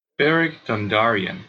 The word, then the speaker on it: Beric Dondarrion: 但是竟然被光之主复活的兄弟会领导人 Beric，呃，这怎么念啊？Beric Dondarrion。